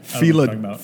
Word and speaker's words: Philodendron: I can't see those engineers lovingly caring for a Monstera or a Philodon. How do you say that? Philodon? – I have no idea. – Philodendron.